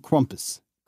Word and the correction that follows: Krampus: Krampus. – I can't do it. – Say it with a hard A. – Krampus.